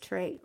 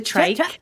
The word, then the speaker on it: trach: I didn't have the, is it trait? What did you, the, how do you say it? I don't know how to say that word. – The trach. There is a whole, hold on. I'm going to get up. – It's not trait.